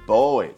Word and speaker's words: buoy: 哎，英文用 buoy 啊，当然有第二个读音叫 boy，就跟男孩一样的读音。